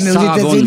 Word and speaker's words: Sarah Vaughan: En France, on dit Sarah Vaughan, t'as remarqué Oui, oui, Sarah Vaughan, vous l'avez bien prononcé.